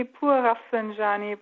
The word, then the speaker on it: Pour Rafsenjani: It's actually Poor Rafsenjani, but who cares about details? – Okay.